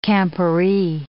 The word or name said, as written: camporee